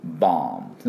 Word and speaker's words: bomb: で、爆弾はボム、 BOM、バーム、バーンですね。あー、 B、 O、ブバブバブバブと、あーで、口閉じて終わり。 B は発音しません。